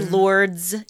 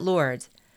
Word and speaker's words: Lourdes: I say Lourdes, even though I shouldn't. I know you don't pronounce the S, but the village in France.